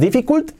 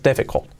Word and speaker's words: difficult: Y no es difficult, es difficult. Fijaos, la T del final casi no la pronuncio.